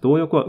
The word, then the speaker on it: 動翼: 動翼は動く翼、翼と書いて動翼と読むんですが、要するにこれ操縦のためのですね、飛行して、まっすぐ飛ぶだけじゃなくて、まあ、曲がったりしなきゃいけないと。